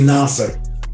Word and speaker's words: Nasar: I think I pronounced that right. – It's actually Nasar.